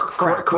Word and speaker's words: craic: So they're like, oh, but it's pronounced crack.